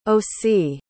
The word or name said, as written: osie